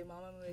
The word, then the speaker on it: Mamamoo: Just say comfortably, Mamamoo is fine. – Mamamoo.